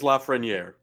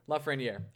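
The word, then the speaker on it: Lafrenière: It is Lafreniere. – Okay. – There you go. – Lafreniere.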